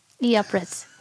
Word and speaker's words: Euphrates: Euphrates.